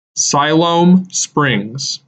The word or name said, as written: Siloam Springs